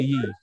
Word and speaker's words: Ye: I mean, no, he's just Ye now. He's supposed to say Ye.